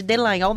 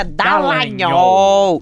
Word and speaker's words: Dallagnol: Em junho de 2019, aliás, me corrija, porque eu sempre chamo ele de Dallagnol, mas a pronúncia é Dallagnol.